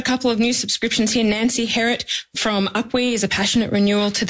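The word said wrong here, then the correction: Upwey: I think it's originally Upway.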